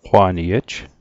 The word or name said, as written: qwa'niej